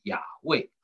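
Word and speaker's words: Yahweh: 那如果你说 YHWH 啊，有时候你会看圣经把这个神啊不翻成耶和华而翻成亚卫，那是因为呢我们在这个亚卫 Y 跟 H 的中间放一个母音 A，那这个是希伯来人的读法，所以他们就读成亚卫、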